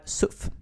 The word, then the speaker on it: CUF: Alltså C ska ju framför hårda vokaler och U är en hård vokal, uttalas som K. Men å andra sidan så heter, alltså det är ju en förkortning för Centerpartiets ungdomsförbund, och att inte använda samma uttal som av Centerpartiet skulle bli konstigt. Därför är jag alltså för SUF.